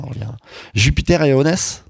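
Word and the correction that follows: Okwess: Et Oquest. – Et Oquest, bon ben voilà, merci beaucoup.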